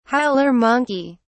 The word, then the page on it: howler monkey: British English